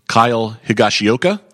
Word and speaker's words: Kyle Higashioka: Kyle Higashioka? – Yes.